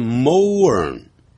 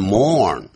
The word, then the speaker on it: mourn: 哀悼你可能会念成 mourn，实际上念成 mourn， mourn。